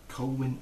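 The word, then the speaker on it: Colwin: Colwyn Uther. As in Colwyn Bay? – Yeah, a little bit.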